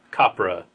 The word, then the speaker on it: Copra: So I'm going to pick a comic called Copra. I think that's how it's pronounced. – Copra? – Copra. C-O-P-R-A.